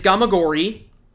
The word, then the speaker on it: Gamagori: Did I pronounce that right? – Yes, you are correct. It is Gamagori.